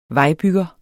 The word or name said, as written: vejbygger